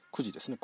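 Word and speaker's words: 公事: くじっていうのは公のことと書いてくじですね、工事と書いてくじ、思う中、中ってのは仲良しの中ですね。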